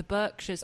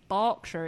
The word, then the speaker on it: Berkshire: the Berkshires, I'm from, well, I went to school in Berkshire. It's spelt the same way, but we pronounce it Berkshire, but Americans pronounce it Berkshire.